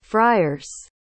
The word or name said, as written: friers